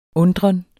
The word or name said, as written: undren